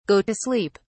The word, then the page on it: go to sleep: British English